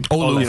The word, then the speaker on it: Olu: I want to see the Jets retire Nick Mangold's number and get another number, Ole. How do you say his first name? – Olu Fashanu.